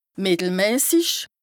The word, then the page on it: mìttelmäsich: Bas-Rhin; Strasbourg